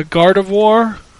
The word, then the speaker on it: Gardevoir: Uh, Gardevoir. Sounds like Garden of War.